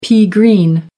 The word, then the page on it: pea-green: American English